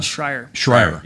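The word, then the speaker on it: Shrier: So, pronounce that woman's name for her, Abigail Schreier.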